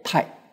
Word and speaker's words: 大学: 啊，这个大学啊，这两个字啊，大这个字啊，在这里啊，念作太。啊，太学啊，古字啊，啊，这个大跟太啊，啊，它是同样啊，用这个字。